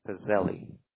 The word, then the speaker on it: Pizzelli: Okay, Pizzelli Marks.